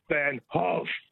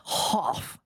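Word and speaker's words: Van Gogh: But it's really pronounced Van Hoff. – Van Hoff, like that. – Not Goff or Go.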